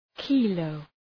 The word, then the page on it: kilo: ‘kıləʋ